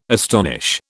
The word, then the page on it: astonish: British English